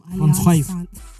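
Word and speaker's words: Alliance Française: Is that how you pronounce it, Alliance Francaise? – Yes. – It's not Alliance Francaise. – No, Alliance Francaise. No, it's Alliance Francaise.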